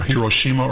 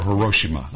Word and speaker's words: Hiroshima: Is it Hiroshima or Hiroshima? I've heard it pronounced either way.